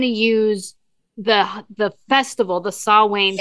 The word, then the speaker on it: Samhain: Now, he's Celtic and the Celtic community kind of was like, please, his name is Samhain. That's how you pronounce the name is Samhain.